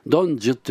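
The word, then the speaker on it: 東柱: ドンジュっていうのは東の柱って書くんですけども、それをドンジュと読むことを禁止されて東中って、まあ、日本語読みしたんですね。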